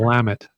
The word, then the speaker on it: Willamette: I said it right that time. – Yes, you got it. – Willamette.